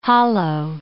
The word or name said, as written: hollo